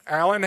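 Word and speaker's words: Allen: You know, for those of you that have ever written my name, Alan Hayes, I grew up, I'm used to people spelling both names wrong.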